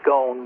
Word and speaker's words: Scone: And then it was taken and it was placed at Scone, which is spelled S-C-O-N-E. A lot of people pronounce it Scone, but the Scots get upset because they say it's Scone.